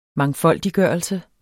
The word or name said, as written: mangfoldiggørelse